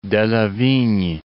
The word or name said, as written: Delavigne